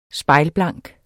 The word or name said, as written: SPEJLBLANK